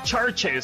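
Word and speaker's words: CHVRCHES: Acuérdense que Churches se escriben con, B, con B de vaca, en vez de la U, en vez de Churches, bueno, pues es C-H-B-R-C-H-E-S. Churches, la canción se llama Good Girls, la canción es nueva, nuevísima, de este 2021, de su álbum Screen Violence.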